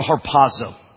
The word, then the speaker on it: harpazo: The original word is called harpazo. That's how you say it in Greek.